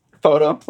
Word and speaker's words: Fauteux: Photo, like photograph.